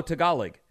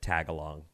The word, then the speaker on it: Tagalog: And she goes, "Oh, Tagalog," and I go, "Yeah." I said Tagalong. Then she goes, "Tagalong? Not even close."